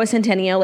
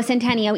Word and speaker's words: Centineo: And like Noah Centineo like being on, you know...